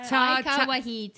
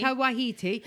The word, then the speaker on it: Taika Waititi: Taki Wakaki. – No. Taika Waititi. – Taika Waititi.